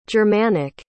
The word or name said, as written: germanic